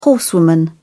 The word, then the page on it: horsewoman: British English